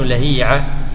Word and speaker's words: لهيعة: اسم الكتاب تالي مشتبه، نسيت الآن اسم الكتاب بالضبط، يقول إن الأسماء ليس فيها قياس، الاسم ما تقدر تنطقه بالقياس، كيف تنطقه إذن بالسماع؟ الآن لا نعرف هل هو عبد الله بن لهيعة لهيعة لهيعة لهيعة، ما نعرف إلا بالسماع.